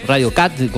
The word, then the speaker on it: Radio Cut: Viste que hay una aplicación que se llama Radio Cut, Radio Cut, como se pronuncia la, palabra cortar, de corte, cut.